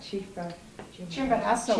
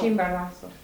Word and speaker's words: Chimborazo: And I just wondered what, apparently it never got to that village in the, how do you say that, Chimborazo. – Chimborazo.